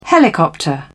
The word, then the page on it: helicopter: British English